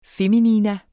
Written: FEMININA

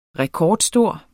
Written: rekordstor

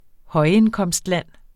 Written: højindkomstland

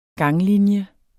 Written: ganglinje

